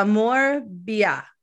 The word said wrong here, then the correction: Morbier: La Morbier.